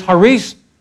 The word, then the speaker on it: charis: The second word is the grace, haris. It's spelled charis, but it's called haris.